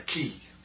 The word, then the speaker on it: quay: And, you know, they call a Peter there a key. – Oh. – And they spell it Q-U-A-Y.